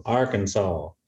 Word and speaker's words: Arkansas: Was it Arkansas? I don't want to get in trouble. – Southern roll on it though. Arkansas.